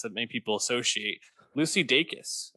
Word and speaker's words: Dacus: I, I, looked up an interview she did, and she said Dacus. So I think right. it's that's right. Uh, I'm going to trust Lucy Dacus on pronouncing her name correctly.